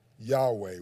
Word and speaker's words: Yahweh: Because the word Jehovah that we said translates into the Lord, the literal Hebrew word for that is YHWH, pronounced Yahweh.